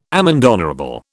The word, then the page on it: amende honorable: British English